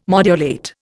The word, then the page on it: modulate: Indian